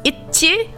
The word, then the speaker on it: ITZY: So the group ITZY, in Korean pronounced ITZY, means the girls have everything you want, itchy in Korean.